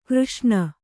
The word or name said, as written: ಘರ್ಷಣೆ